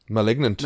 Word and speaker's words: Malignant: Um, well, I was going to suggest Malig- Maligant. Malignant. Malignant. Oh my god, did I really just say that? I butchered that, mm.